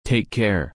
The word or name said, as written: Take Care